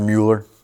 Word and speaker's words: Mueller: You guys know who George Mueller is, or Mueller? Do you know who he is? Okay, George, it depends. You can pronounce it either way. It's tomato, tomato, Mueller, Mueller.